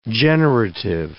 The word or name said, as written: generative semantics